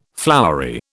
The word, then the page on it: flowery: British English